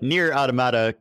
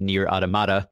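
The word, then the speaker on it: NieR: Automata: But But Nier Automata came out about a month ago, which is roughly how long it's taken me to learn to pronounce it Nier Automata instead of Nier Automata.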